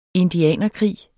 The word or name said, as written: indianerkrig